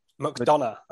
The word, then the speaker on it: McDonagh: McDonough, I believe it is. – McDonough. Okay. Let's go with that then. – Not Madonna, like, you know, Vogue. McDonough.